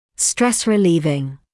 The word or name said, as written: stress-relieving